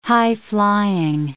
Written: high-flying

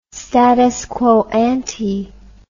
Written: status quo ante

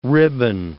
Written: ribbon

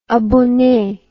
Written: abonne

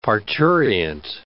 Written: parturient

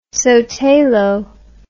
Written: sotelo

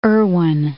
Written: Irwin

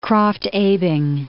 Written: Krafft-Ebing

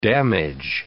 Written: damage

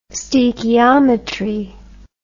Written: stoechiometry

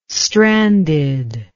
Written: stranded

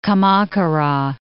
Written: Kamakura

